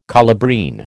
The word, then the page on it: colubrine: American English